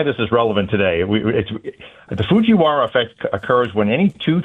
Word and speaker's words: Fujiwara: It's the Fujiwara. – Fujiwara. I got that right? – Fujiwara effect.